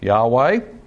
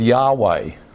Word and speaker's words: Yahweh: And the Jews didn't like pronouncing it, so they put in the vowels of Adonai over what we now think is probably pronounced Yahweh.